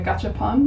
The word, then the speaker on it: gachapon: A gacha system is, if you aren't aware of what a gacha system, it's basically named after a gachapon machine.